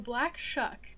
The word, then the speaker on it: Shuck: Shuck? It's like shucking corn? – The Black Shuck. – Or like chuck, spelled? But you pronounce the C-H. – S-H-U-C-K. – Oh, so it is spelled like shuck.